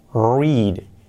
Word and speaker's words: read: Se escribe read, read, read, pero cuidado que la pronunciación es read, read, read. Así, read. Por ejemplo, no leo todos los días.